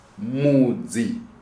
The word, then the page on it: Mudzi: Shona